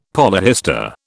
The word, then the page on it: polyhistor: British English